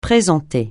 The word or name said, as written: présenter